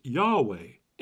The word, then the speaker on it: Yahweh: In Hebrew, God's name is pronounced Yahweh, and out of reverence for God's holy name, the word Lord was often spoken in its place.